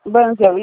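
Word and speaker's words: Bunzl: Só para você estar ciente que tem muitos funcionários da empresa, eu não sei nem como que pronuncia, é Bunzel, Bunzel. – Banzel, isso. – Ah, é chique, é Banzel.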